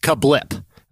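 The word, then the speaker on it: CABLT: It is spelled C-A-B-L-T. C-A-B-L-T, and it's pronounced Kablip.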